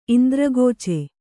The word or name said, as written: ಇಂದ್ರಗೋಚೆ